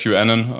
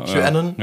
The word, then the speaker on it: QAnon: Ich sage Sag, QAnon. QAnon.